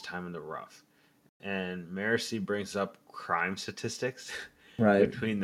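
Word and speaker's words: Marasi: How do you pronounce her name again? Is it Morassi? – Morassi, yes, sir.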